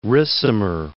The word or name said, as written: Ricimer